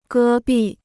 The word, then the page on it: 戈壁: gē bì